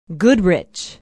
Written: goodrich

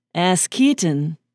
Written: askeaton

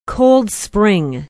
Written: Cold Spring

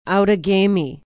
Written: Outagamie

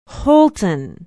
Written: holton

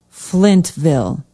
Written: Flintville

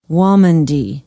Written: waumandee

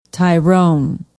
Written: tyrone

